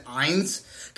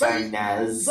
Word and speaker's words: Inez: I think I said Ines. God, I'm so stupid sometimes. Like, I don't know how to pronounce people's names. Jesus Christ. Yeah, I was like, Ines from The Rats. I think, I'm pretty sure I said that. That rings a bell. – It's Inez.